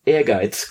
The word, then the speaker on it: Ehrgeiz: having this name pronounced correctly for the first time on Retronaut, Ehrgeiz, God Bless the Ring.